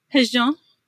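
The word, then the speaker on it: hózhó: Tell me, as we come to the close here, tell me a little bit about, I'm going to mispronounce it, I'm sure, Hojo? – Hejon. – Hejon. – Yes. Yeah. Hejon.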